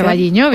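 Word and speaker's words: O Carballiño: Vamos a hablar de la fiesta del pulpo, que se celebra en Ocarvalliño. No lo sé pronunciar. – Ocarvalliño, bien.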